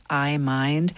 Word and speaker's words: iMind: So iMind is sort of spelled like iPhone, but it's iMind, and it stands for I, multimodal imaging, in neurodegenerative disease.